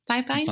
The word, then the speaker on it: Fifine: I don't know how to pronounce this, but it's F-I-F-I-N-E. Fifine? Fifine? – Fifine.